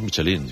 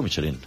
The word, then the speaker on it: Michelin: A ver, aquí en la Península Ibérica hacemos Michelin. – Sí, claro, pero la realidad, tú eres un chef internacional. – Ya, pero... – ¿Tú cómo lo pronuncias? – Me cuesta ser tan tontorraco, ¿no? Dice Michelin. – ¿Michelin? – Yo digo Michelin. – Vale. – Y cuando vas a una gala, pues escuchas Michelin a veces.